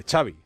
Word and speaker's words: Xavi: Nah, tiene gracia porque lo primero que le pregunta es cómo se pronuncia Xavi Hernández y dice Xavi, like, como chocolate, Xavi, chocolate, Xavi Hernández.